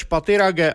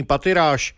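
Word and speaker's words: Pathirage: Vyslovoval své jméno Rumeš Patirage a vyloučil anglickou výslovnost svého příjmení Patiráš.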